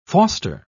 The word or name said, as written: foster